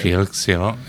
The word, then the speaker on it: Felix Silla: We got Carol Spinney, who played Big Bird, Hippie Tom, Felix Silla, right? Is that how I'm saying that right? – Felix Silla, yeah.